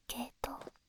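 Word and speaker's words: 鶏頭: ええ、ちょっとした豆知識ですが、ケイトウというのは鶏のトサカに形状が似ていることから、鶏の頭と書いてケイトウと読むんですよ。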